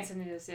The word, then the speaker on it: Encinitas: Followed by, and I'm going to mispronounce this, Encinitas, California? – Oh, yeah. – Okay. – Encinitas, yeah.